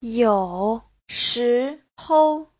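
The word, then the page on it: youshihou: you3shi2hou5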